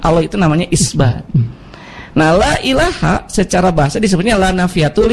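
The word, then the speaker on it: la ilaha (لا إله): Jadi kalau kan dibacanya la ilaha kan, bukan la ilahun kan?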